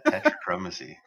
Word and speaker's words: tetrachromacy: Now, do I get any credit for pronouncing it correctly on my first try? – You absolutely do. Yeah, tetrachromacy.